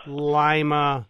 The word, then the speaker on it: Lima: And it's Lima, Peru, but your town is pronounced how? – Lima. – Lima, of course. – Lima as like the bean. – Like a lima bean.